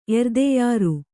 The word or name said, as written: ಎರ್ದೆಯಾರು